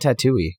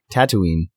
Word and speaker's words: Tatooine: Why do you call it Tatooine? – Fuck, how do you pronounce it? – Tatooine. I thought you were doing that on purpose.